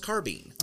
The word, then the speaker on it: carbine: This carbine or carbine? Is it carbine? – As far as I know, it's pronounced carbine. – Okay. I don't know. – Kind of the same way that a carabiner is pronounced a carabiner. – I'm learning. – Instead of a carabiner.